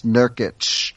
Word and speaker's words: Nurkic: Those IC names, instead of Nurkic, it's Nurkic. That's how they're all pronounced. It's like a cheat code.